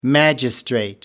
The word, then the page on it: magistrate: American English